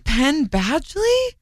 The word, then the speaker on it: Penn Badgley: And Penn Badgley? – Yeah, that's how you say it.